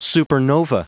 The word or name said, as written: supernova